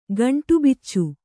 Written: ಗಂಟುಬಿಚ್ಚು

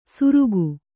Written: ಸುರುಗು

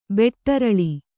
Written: ಬೆಟ್ಟರಳಿ

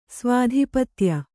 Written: ಸ್ವಾಧಿಪತ್ಯ

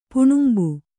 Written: ಪಣಂಬೆ

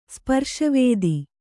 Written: ಸ್ಪರ್ಶವೇದಿ

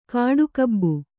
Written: ಕಾಡುಗೂಬೆ

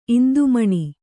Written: ಇಂದುಮಣಿ

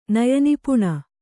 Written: ನಯನಿಪುಣ

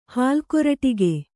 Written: ಹಾಲ್ಕೊರಟಿಗೆ

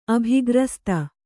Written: ಅಭಿಗ್ರಸ್ತ

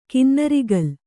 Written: ಕಿನ್ನರಿಗಲ್

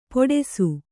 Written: ಪೊಡೆಸು

